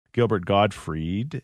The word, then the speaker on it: Gilbert Gottfried: Gilbert Gottfried, is that how you say his name? – That's correct, yeah.